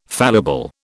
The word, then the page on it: fallible: British English